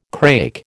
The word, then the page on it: crake: American English